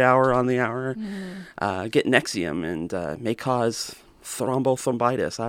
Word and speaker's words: NXIVM: But first, you have probably heard about this group called Nexium, or as it's spelled, N-X-I-V-M, which, you know, it seems like it should be a running shoe or something, or...